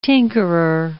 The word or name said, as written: tinkerer